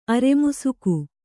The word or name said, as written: ಅರೆಮುಸುಕು